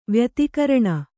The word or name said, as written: ವ್ಯತಿಕೀರ್ಣ